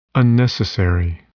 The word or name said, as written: unnecessary